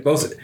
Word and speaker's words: boatswain: And it's boatswain. Right? Am I saying it? – Bosun. – It's bosun. So I said boatswain because that is how it is spelled. And I was wrong.